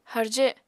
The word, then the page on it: harje: apatani